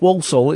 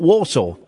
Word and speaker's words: Walsall: Yes, oh yes, for years I only recently found out that Walsall is Walsall is pronounced Walsall. It's not Warsaw.